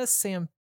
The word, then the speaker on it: Sampaio: And then they also casted Sarah Sampaio.